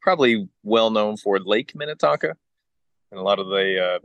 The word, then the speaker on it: Minnetonka: Did I say that right? – Yes, sir. Redeemer Bible Church in Minnetonka, Minnesota. – Okay. I keep saying that wrong. Minnetonka. – Minnetonka.